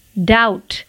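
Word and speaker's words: doubt: डाउट D O U B T डाउट यहाँ पर B silent है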